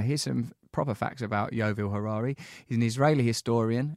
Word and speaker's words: Yuval: I'm saying your name correctly, Yuval. – Yuval. Yeah, like it's written. There is no secret there.